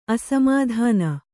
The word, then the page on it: ಅಸಮಾಧಾನ: asamādhāna; Kannada